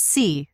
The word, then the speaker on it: Seay: Is it Eric Say or Eric C? – It's C. – C. Okay. – Yeah. – And that's spelled S-E-A-Y. – Yeah.